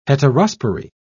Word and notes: heterospory: British English

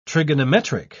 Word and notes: trigonometric: British English

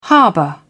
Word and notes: harbour: British English

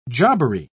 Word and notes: jobbery: British English

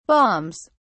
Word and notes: balms: British English

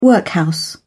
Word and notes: workhouse: British English

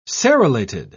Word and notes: serrulated: British English

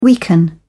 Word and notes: weaken: British English

